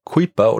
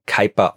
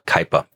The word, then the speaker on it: Kuiper: Deswegen denken manche, man würde ihn Kuiper oder sowas aussprechen. Aber er ist nach Amerika ausgewandert, ist auch Amerikaner geworden. Und er hat sich auch selbst Kuiper ausgesprochen. Also sage ich auch Kuiper.